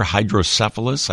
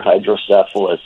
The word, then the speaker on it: hydrocephalus: Normal pressure hydrocephalus.